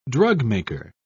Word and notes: drugmaker: British English

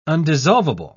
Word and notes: undissolvable: British English

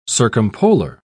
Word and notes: circumpolar: British English

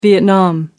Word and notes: vietnam: American English

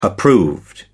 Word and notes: approved: American English

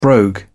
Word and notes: brogue: British English

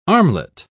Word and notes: armlet: British English

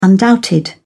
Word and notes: undoubted: British English

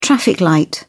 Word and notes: traffic light: British English

